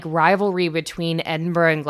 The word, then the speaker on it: Edinburgh: It looks like, and this is for all my American friends, it looks like Edinburgh. It is most assuredly motherfucking not. So don't pronounce it that way.